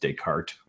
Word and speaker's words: Descartes: And by the way, guys, it is actually, his name is pronounced Descartes without the either of the S's, I guess, which makes it even funnier.